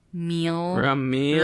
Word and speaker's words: Rhumeal: So, R-H-U-M-E-A-L. Oh, wow. Because my name is Rumeal. Do you get it? Rumeal. – Rumeal. – Rum, like the alcohol. So, it's at R-H-U-M-E-A-L.